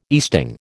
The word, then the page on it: easting: American English